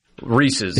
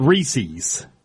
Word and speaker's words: Reese's: Reese's. It's not Reese's. Reese's, thank yeah. you, because it's Reese's Pieces. It rhymes for a reason.